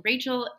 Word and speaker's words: Rachael: So I primarily post on Instagram at rachel.ridge, and Rachel is spelled R-A-C-H-A-E-L, because my family likes to make that a little difficult.